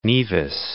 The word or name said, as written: Nevis